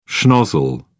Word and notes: schnozzle: British English

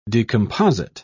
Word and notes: decomposite: British English

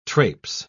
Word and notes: trapes: British English

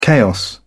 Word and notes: chaos: British English

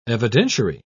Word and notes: evidentiary: British English

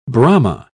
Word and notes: brahma: British English

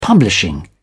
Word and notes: publishing: British English

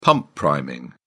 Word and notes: pump priming: British English

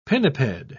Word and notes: pinniped: British English

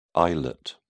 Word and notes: islet: British English

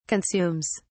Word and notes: consumes: British English

